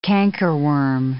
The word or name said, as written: cankerworm